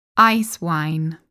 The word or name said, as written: Icewine